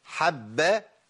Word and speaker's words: حبب: لازم انطق ايه؟ ثلاث باءات، ثلاث باءات. اقول ايه؟ وحبب اليكم، ما اقولش وحبب اليكم، وحبب اليكم، ما ينفعش يا شيخ. والله ما ينفع. ما ينفعش ان انا اسقط باء من الثلاث باءات، ما هو حبب باء.